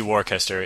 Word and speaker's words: Worcester: This is spelled Worcester, so if you see Worcester, it's pronounced Worcester.